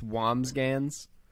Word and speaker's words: Wambsgans: That was that was my answer and I did just pronounce it the correct way. It's Wambsgans.